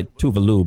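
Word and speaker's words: Tove Lo: Then you got Tovlo. Technically, she goes by Tuvalu, but no one pronounces it right.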